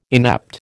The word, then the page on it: inept: American English